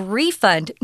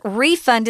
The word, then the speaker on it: refund: Notice refund is a noun, but if you use it as a verb, the pronunciation's different, guys. Refund, refund, refund. But a refund is the noun. They'll still understand you if you get it wrong, but try to remember there's a difference in pronunciation.